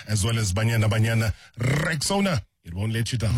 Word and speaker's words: Rexona: A brand that you've trusted for years and years and years is stepping forward with a bold new look, and it's pronounced Rexona.